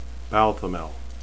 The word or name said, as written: balthamel